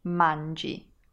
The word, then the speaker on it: mangi: Ending in I, pronounced as E. Mangi. M-A-N-G-I.